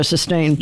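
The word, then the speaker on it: Systane: I think it's pronounced Sustane. – That's over-the-counter stuff. Yeah, Sustane.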